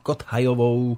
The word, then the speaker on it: Kothajovou: Tak to je pesnička, ktorá nám teda urobila takéto hudobné antré do nášho dnešného rozprávania e, s Jankou Kothajovou. E, takto sa to má vyslovať správne? E, áno, TH, Kothajová. Nemalo by sa to moc,